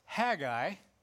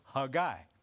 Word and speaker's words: Haggai: We typically have referred to it as Haggai. It's Hebrew, of course, and so I, through this message, am going to refer to his name in the Hebrew pronunciation, which is Haggai, okay?